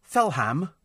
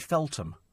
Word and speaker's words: Feltham: He said, Felham. So I said, pardon? Felham. He's in Coventry, this bloke. I said, where in God's name is that? Turns out to be Feltham. He couldn't pronounce it.